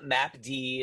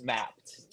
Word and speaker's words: Mappd: It's not map D, it's not MD app, it's just mapped. Just put an E in there and pronounce it like that. Yeah. We just couldn't afford the E. – There's like an invisible apostrophe, right? So it's MAPP invisible apostrophe D. Just say map.